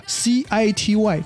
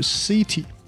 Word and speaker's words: city: 这个地方容易读错的几个地方呢，一个是包含刚才我说的两个连读的地方，还有一个是这个 c i t y 城市，不是 city，是 city city city，它是 a 不是 e 啊。